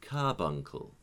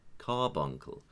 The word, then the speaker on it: carbuncle: and they said carbuncle. And I said, well, it needs to be more ah, carbuncle.